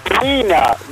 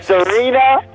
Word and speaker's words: Serena: My name is Serena with an R, no B, an R. Serena...